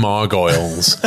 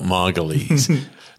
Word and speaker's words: Margolyes: Miriam Margoyles, as everybody's known her for 40 years. – Now she's rebranded. – But now we've actually realised that her name is spelt Margolyes. – That's the way she pronounces her own surname.